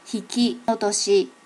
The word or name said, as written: hikiotoshi